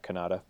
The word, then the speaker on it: Kanata: I know it's in Kanata. Kanata. I believe that's how you pronounce it.